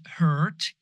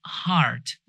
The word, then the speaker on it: heart: And then not hurt, it's heart, your heart, an organ. Heart diseases or heart disease, right? So heart, it's like an ah long heart disease, right?